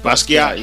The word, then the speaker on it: Basquiat: And the second one was a biography, pretty much, of Jean-Miguel Basquiat. Did I say that right? – Basquiat. Yeah.